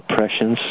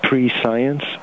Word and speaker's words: prescience: and it's like pre-science, but it's pronounced prescience.